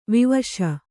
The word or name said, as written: ವಿವಶೆ